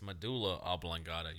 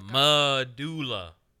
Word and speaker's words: medulla oblongata: No, it's medulla oblongata. You're saying it wrong too, bitch. – Mandula oblongata. – No, medulla.